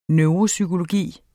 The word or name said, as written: neuropsykologi